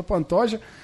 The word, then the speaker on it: Pantoja: Como os americanos gostam de falar, né? Não conseguem pronunciar o J, Pantoja, que ganhou do Eric Shelton.